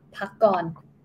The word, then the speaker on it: Pakorn: P-A-K-O-R-N. – Yeah, pakon. Pakon. Mm-hmm. Actually, the last syllable, it has to go down.